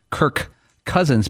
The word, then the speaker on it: Kirk Cousins: Dan, if you remember the day of that show, we reached out to a linguist, a professor at a university, and he said you were not wrong in the way you pronounce Kirk Cousins, because when you say it quickly, the last K of Kirk and the first C of Cousins are almost redundant.